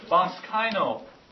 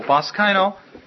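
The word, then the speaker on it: baskaino (βασκαίνω): That translates this Greek word, baskaino. Spelled B-A-S-K-A-I-N long O, baskaino.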